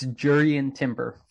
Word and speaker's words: Jurrien Timber: Just tell me how to say it. – It's Jurrien Timber from the man himself. Yep. – Okay, so we're going with Jurrien.